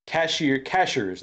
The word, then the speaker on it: Cashiers: That was the only time we saw anything, which is nice, because we went down to Cashiers, they call it, not Cash, it's spelled Cashiers, Cashiers, North Carolina, right? – Mm-hmm.